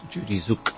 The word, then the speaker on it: Tzuke: And it's spelled T-Z-U-K-E, but the T is sort of almost silent, so it's Judy Zook.